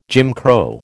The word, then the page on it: Jim Crow: American English